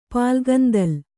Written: ಪಾಲ್ಗಂದಲ್

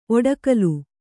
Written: ಒಡಕಲು

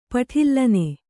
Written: ಪಠಿಲ್ಲನೆ

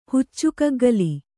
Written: ಹುಚ್ಚುಕಗ್ಗಲಿ

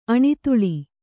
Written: ಅಣಿತುಳಿ

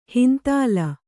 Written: ಹಿಂತಲೆ

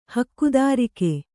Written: ಹಕ್ಕುದಾರಿಕೆ

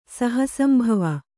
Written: ಸಹಸಂಭವೆ

